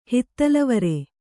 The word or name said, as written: ಹಿತ್ತಿಲವರೆ